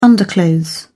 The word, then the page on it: underclothes: British English